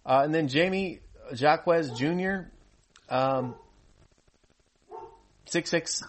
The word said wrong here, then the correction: Jaime: Jaime Jacquez.